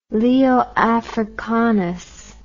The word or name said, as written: Leo Africanus